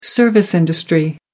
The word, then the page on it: service industry: American English